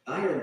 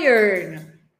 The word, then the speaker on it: iron: Iron clothes. – Ah, aqui eu não posso deixar de falar sobre a pronúncia, né, queridos? Iron. Quem aqui pronuncia errado? A pronúncia aqui é iron.